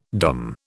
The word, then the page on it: dumb: British English